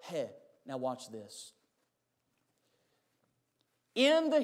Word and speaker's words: he: We actually, you'll say it like hey, H-E-Y, but it's the letter he, fifth letter.